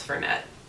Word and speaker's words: Fernet: I said Fernet for years. – Oh, really? – Yeah. Hmm. And it was like, oh, it's Fernette.